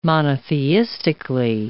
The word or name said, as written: monotheistically